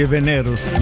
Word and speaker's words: Venerus: Ed io che stavo pensando ancora dove mettere l'accento, nel Venerus o Venerus? Venere, quindi Venerus, Venere, Venerus.